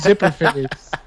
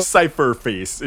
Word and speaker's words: Cypherface: I thought it was Zipperface. – No, Cypherface is just spelt with.